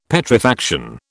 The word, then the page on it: Petrifaction: British English